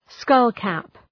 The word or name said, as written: skull-cap